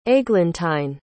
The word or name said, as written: aiglentine